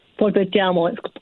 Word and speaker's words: Polpettiamo: Did I get the name right, Polpettiamo? Polpettiamo, yes, oh. absolutely right. I appreciate the fact that you, the pronunciation is Italian.